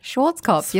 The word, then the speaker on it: Schwarzkopf: Schwarzkopf. You're saying it right.